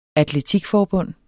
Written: atletikforbund

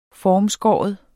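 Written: FORMSKÆRE

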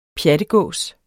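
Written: PJATGÅS

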